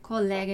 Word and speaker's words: collega: Collega in italiano, collega in português. Então, le due L qua, né? Os dois L's, prestar atenção na pronúncia.